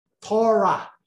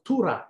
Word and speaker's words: Torah: Torah. But in actuality, you pronounce it Tura in Paleo-Hebrew pronunciation.